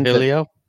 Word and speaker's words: Trujillo: Trujillo.